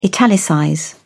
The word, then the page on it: italicize: British English